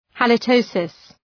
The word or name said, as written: halitosis